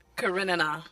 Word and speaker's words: Karenina: Karenina.